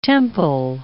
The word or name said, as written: temple